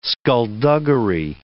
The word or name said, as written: skulduggery